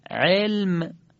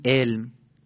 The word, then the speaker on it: علم: مثلا مینویسیم علم، ولی چی میخونیم؟ میخونیم علم. با عین مینویسیم، با الف میخونیم.